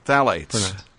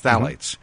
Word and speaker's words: phthalates: There's a thing called phthalates. T-H-A-L. Actually, it's not spelled that way, but phthalates. – Yeah. – It's with a silent P-H, so it's phthalates or phthalates.